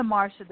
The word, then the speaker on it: Marsha: I'm the Marsha, that's the S-H-A, so it's M-A-R-S-H-A. And my last name is spelled W-I-E-T-E-C-H-A.